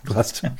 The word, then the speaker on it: Gloucester: You call it Gloucester. – Gloucester. Yeah, that's right.